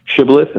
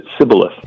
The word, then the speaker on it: shibboleth: because one group pronounced it shibboleth and the other pronounced it sibboleth.